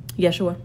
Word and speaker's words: Yeshua: Yeshua.